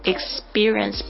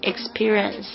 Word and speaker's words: experience: 就是这个 t、 p、 k 这些声音呢，在 s 后面呢就会变成 b、 g 的这些声音啊。所以呢也是一样， experience 不读成 experience，把它记起来。